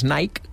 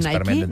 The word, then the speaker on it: Nike: Perquè hi ha tradició històrica a Catalunya de dir-ne Nike. – I per què diem Nike? No, i per què diem Nike? – Perquè és la pronúncia anglesa. – Per què no diem Nike? – No, perquè és la marca, no és el nom de la deessa, és la marca de roba esportiva.